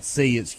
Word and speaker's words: Cy: Give them three post players along with C, and it's spelled Cy, but it's pronounced C, and Carlos Curry.